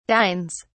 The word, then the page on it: dines: British English